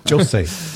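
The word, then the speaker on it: José: Jose. – Yeah, how would you say it? – Jose. – Oh, Ooh. – Jose. It should be a bit more clipped from what I'm told.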